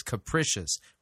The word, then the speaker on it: capricious: By the way, that's how you say it, Doug. It's capricious. Rhymes with delicious, just so you know.